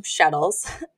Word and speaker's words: shtetl: So like my family came from Shtetls. – Shtetl. It rhymes with like kettle.